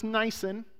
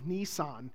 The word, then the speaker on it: Nisan: Now, that's pronounced Nisan, because I researched it. I had all kinds of Nissan jokes.